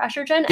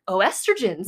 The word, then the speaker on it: oestrogen: And in Britain, they use estrogen and they spell it by putting an O before estrogen. – Yeah, it looks like oestrogens. – In my head, I'm reading this and I'm like, oestrogens. – Yeah. – Actually, it's pronounced estrogen.